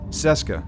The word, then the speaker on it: Seska: Like, is she going to be like, is she going to be like a Keska? Did I say that right? – Seska. – Sorry, God. Seska.